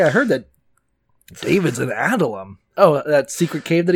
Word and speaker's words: Adullam: It's a cave in Judah known as Adullam. I don't know how to say it. – You got it.